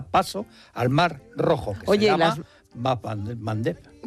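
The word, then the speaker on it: Bab el-Mandeb: Bap el Mandeb. – Bap el Mandeb, esa me la voy a apuntar. – Cuesta un poquito pronunciarlo, por eso en Occidente se dice el Mandeb.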